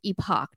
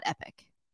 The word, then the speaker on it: epoch: It's epoch, not epic. – Was somebody asking that? – A couple people said that they pronounced the epoch tees epic, but it is not epic. It is epoch.